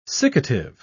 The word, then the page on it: siccative: British English